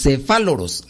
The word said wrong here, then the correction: cefalóforos: ándele, No, los cefalóforos los cefalóforos. ver, es una palabra griega que, no, no está difícil de pronunciar.